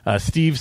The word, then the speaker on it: Steve: Steve, spelled with a V, S-T-E-V-E. And my last name is V-A-N-K-E-U-L-E-N.